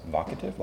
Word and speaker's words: vocative: It's not vocative. I've been mispronouncing this. So it's vocative, like evocative.